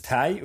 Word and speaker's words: Ti: Aber anscheinend spricht man es Ti aus. Ist eigentlich auch logisch. – Native Englisch? – Anscheinend, ja. Kommt davon, weil es ja die Kurzform von Titanium ist.